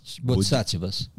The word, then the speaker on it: Bodhisattva: Tantra Yoga e Kundalini, e se esse seria o segredo dos bodhisattvas. Bodhisattvas, isso. É isso? Falei certo? Falou certíssimo, Bodhisattva.